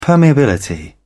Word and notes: permeability: British English

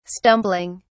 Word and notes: stumbling: British English

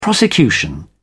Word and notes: prosecution: British English

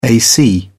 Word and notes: ac: British English

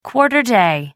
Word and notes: quarter day: American English

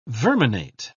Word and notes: verminate: British English

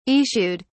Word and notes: issued: British English

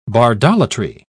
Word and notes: bardolatry: British English

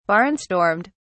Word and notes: barnstormed: British English

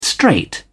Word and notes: straight: British English

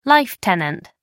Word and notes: life tenant: American English

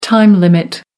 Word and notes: time limit: American English

